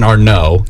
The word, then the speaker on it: Arnott: Jason Arnott or Arnaud? – I think it might be Arnaud. – I think it's Jason Arnaud. – Yeah. – It's spelled like Arnott.